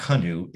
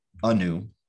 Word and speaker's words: Anu: Anu is coming here, and it's not Hanu, it's Anu, I believe. – Anu. – Yeah. The H is silent. I saw on Twitter numerous times, podcasters, here's what you need, how you need to pronounce it. So it's Anu.